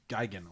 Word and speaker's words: Gigan: I had Godzilla versus Gigan, or Gigan, as they pronounce it both ways throughout the entire movie.